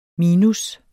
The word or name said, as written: minus